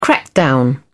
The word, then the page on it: crackdown: British English